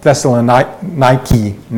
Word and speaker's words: Thessaloniki: It's pronounced Thessaloniki now, and it's a huge modern city.